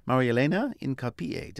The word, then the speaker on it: Marielena Hincapie: And today we're talking immigration, and to help us understand that topic, which is becoming even hotter as we speak, is Marielena Hincapie.